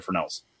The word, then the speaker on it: Fresnel: There's like silent S in there. – Oh, F R E S N E L. Yeah. There we go. – Yeah. It's like Fresnel.